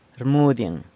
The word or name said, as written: rmudyeng